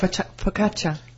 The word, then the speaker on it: focaccia: And just to actually learn how to say focaccia. – You just learned. – Focaccia. Because I used to say, can I have some focaccia, please? And I thought I was really saying it correctly.